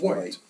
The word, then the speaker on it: Whyte: K-E-R-R-I-T-H, and his last name is W-H-Y-T-E, which I believe is White.